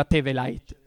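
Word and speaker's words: Theweleit: non so se sia questa la vera pronuncia, ma perché in tedesco dovrebbe essere Teveleit, però è un cognome ebreo, quindi forse non si pronuncia così. Lo diceva Teveleit, ecco, perfetto.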